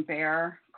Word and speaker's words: Beijer: This is a piece from Carl, I think you pronounce his name Bear, Carl Bear, B-E-I-J-E-R.